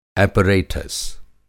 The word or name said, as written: एपरेटस